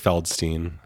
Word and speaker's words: Feldstein: I mean, Beanie Feldstein, am I getting her name right? Because I feel like we've been messing with her name. Because we had our own version for her name. – It is Feldstein.